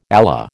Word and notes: Allah: American English

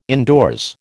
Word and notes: indoors: American English

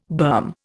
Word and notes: berm: Indian

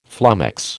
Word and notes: flummox: American English

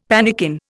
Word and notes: pannikin: Indian

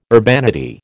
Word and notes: urbanity: American English

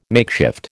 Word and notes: makeshift: American English

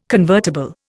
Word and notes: convertible: Indian

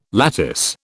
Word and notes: lattice: British English

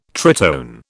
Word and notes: tritone: British English

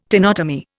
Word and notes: tenotomy: Indian

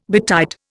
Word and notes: betide: Indian

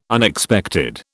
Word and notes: Unexpected: British English

